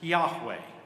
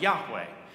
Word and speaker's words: Yahweh: In Hebrew, it's believed to be pronounced Yahweh.